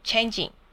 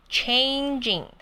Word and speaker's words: changing: 第二个要注意的是 changing，大家都喜欢念成 changing，有一个很像在念千的那个音，千景，不，它是 changing，它有那个 chain 的音， changing， OK， changing，好，拉长一点哦。